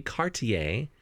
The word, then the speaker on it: Cartier: The multi-billionaire owner of luxury jewelry company Cartier, and I know how to pronounce that because I was just in Paris, has revealed his greatest fear.